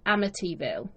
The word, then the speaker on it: Amityville: To me, over here, I think we always hear is Amityville. – Yes. – But the actual American pronunciation, I believe, is Amityville.